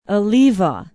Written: eleva

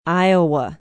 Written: Iowa

